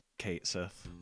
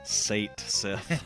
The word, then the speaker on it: Cait Sith: I think everyone agrees, Kate Sith. I think it's hilarious. Bizarro put in this thread that when he was younger, he pronounced it as Sate Sith.